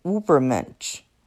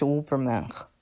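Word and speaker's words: Übermensch: 最后我得纠正一下刚才我的发音，应该是 Ubermensch，不是 Uberman。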